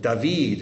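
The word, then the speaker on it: David (דוד): David is how it's pronounced in Hebrew. Dalet, vav, dalet.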